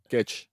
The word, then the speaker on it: Guedes: I think Gonzalo Guedes is, am I pronouncing that right? – Gedge. – Gedge. Oh, right. Wow. Really? – Yeah. It's Portugal. – Gedge.